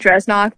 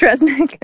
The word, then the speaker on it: Dresnok: It's like written like Dresnok, but it is pronounced Dresnick.